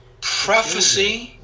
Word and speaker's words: prophecy: Prophecy is a noun. Prophesy is a verb. Do you see the difference in the pronunciation? Prophecy, prophesy.